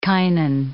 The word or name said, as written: kinin